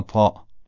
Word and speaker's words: ط: تی دو نقطه خب تی معمولی است که در فارسی به کار میبریم، حالا همین تی دو نقطه رو بیایم غلیظش بکنیم میشه طا. یعنی تلفظ از همون جایی صورت میگیره که تی دو نقطه صورت میگیره، با این تفاوت که طا درشته اما تی دو نقطه این درشتی رو نداره.